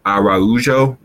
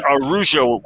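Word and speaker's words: Araújo: We got Luis Araujo. I got to get that pronunciation right. Because it's not Spanish, it's Brazilian, it's Portuguese, so it's a little different. – Araujo would be then, right? Instead of Araujo.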